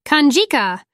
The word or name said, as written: canjica